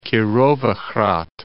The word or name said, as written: Kirovohrad